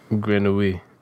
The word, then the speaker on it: Grenouille: Grenouille.